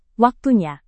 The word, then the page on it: waktunya: Indonesian